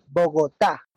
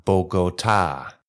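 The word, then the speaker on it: Bogotá: Bogota? – The last A has accent, Bogota. – Ah, Bogota.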